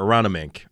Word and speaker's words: Aronimink: It's Aronimink Golf Club, right? Before we go ahead, because the name pronunciation has been all over. Aronimink. Okay. – It's M-I-N-K. Yes, actually, I talked about it on the show.